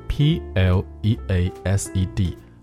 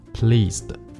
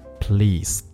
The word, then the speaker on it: pleased: 但是要注意的是啊，这个 P L E A S E D pleased 这个发音不是念 s，而是念 z，pleased。不是 pleased，这是有区别的。